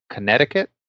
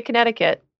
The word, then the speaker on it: Connecticut: New York, New Jersey, and Connecticut, to which I was informed most people don't say Connecticut. And really? I said, I like that there's a silent C in it.